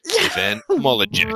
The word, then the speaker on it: Molyneux: and then it's like, Stefan Molyneux is, and it's just like, okay, that only works because you don't know about the silent X at the end. Stefan Molyneux.